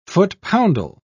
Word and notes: foot-poundal: British English

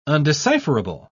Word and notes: undecipherable: British English

